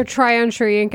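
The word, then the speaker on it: Joe Tryon-Shoyinka: And to just go off improvements, I think I don't even know how to say his name, Tyron Shoyinka, was that right? – Yeah, Joe Tryon Shoyinka, I think is how we say it.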